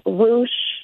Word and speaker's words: Roosh: That was basically the crux of what their leader, who is this, he's an American blogger who goes by the name Roosh. He goes by different last names. – Which rhymes with douche?